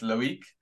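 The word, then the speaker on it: Loïc: Uh, we used to call him Loic Remy. Uh, yeah. John tells me it's Loic. Yeah. – Yeah, yeah. So I pronounced it wrong to start with, but uh, yeah.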